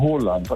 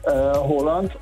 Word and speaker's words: Haaland: Er wird ausgesprochen Haaland, also mit O, ja. – Haaland. Und zwei A's werden als O ausgesprochen und ein A mit einem Kringel oben drauf ist eigentlich auch eine Aussprache, ein O.